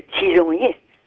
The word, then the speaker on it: tisonnier: That rod you poke the fire with, in French, we used to call it le tisonnier. – Le tisonnier, yeah. – That's it. – Yeah. – How do you say it again? – Tisonnier. – Yeah. – And that's the way we pronounce it.